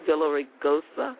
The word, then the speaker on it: Villaraigosa: Villaraigosa.